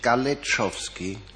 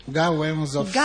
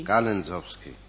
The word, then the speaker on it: Galensowski: Heißt er nicht Galetschowski? – Galensowski. – Galensowski, das wird geschrieben Gustav Anton Ludwig Emil Zeppelin Otto Wilhelm Siegfried Kaufmann Ida. – Und wird ausgesprochen? – Galensowski. – Galensowski.